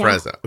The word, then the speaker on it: Afrezza: You actually, like, so how long have you been using the, is it, by the way, I'm sorry, is it Afrezza or is that how they say it? Afrezza? – Afrezza.